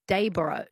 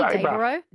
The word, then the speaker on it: Dayboro: Daybra. It's spelled O-R-O through me. – It Yeah. – is. – It should be Daybra, Brah.